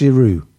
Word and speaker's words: Giroud: You don't pronounce the D. No one pronounces the D. It's just Giroud. It's not Giroud.